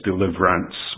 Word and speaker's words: deliverance: you wouldn't pronounce the E, so it's deliverance would fit the tune in that line.